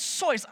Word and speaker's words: Seuss: It's Seuss.